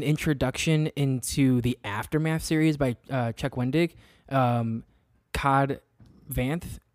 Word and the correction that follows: Cobb Vanth: is that how you say his name? – Yeah, Cobb Vanth.